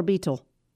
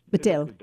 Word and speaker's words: Betel: Again, what began as an 8-man in an apartment becomes, was it Betel or Beetle? – Betel. Like Bethel, which is House of God in Hebrew, but with a Spanish sort of pronunciation.